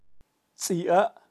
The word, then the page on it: tzi: Kaqchikel